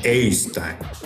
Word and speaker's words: Jostein: Did I pronounce well? – Yeah, pretty close. Actually, pretty close. – And what is the perfect pronunciation? – Jostein. Jostein. – Jostein. Okay. – You know, there is a sound to it that, you know, it's very Norwegian.